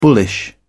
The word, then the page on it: bullish: British English